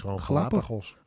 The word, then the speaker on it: Galapagos: Glap, ik kan dat woord nooit uitspreken. – Galapagos. – Galapagos.